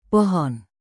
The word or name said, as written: pohon